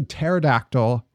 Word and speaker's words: pterodactyl: That is a pterodactyl with a silent P, and I don't like it.